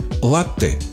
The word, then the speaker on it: латте: Латте или латте. Правильный вариант – латте с ударением на первый слог.